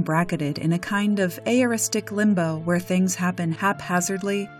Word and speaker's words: aoristic: It's aoristic. That's spelled A-O-R-I-S-T-I-C.